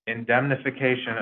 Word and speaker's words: indemnification: but I'm gonna try indemnification of directors and officers.